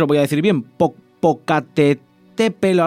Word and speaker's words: Popocatépetl: Yo es que estoy acostumbrada ya a nombrarlo, porque claro, como está tan de moda últimamente, es Popocatépetl.